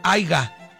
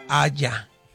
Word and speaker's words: haya: Y no se dice haiga, se dice haya.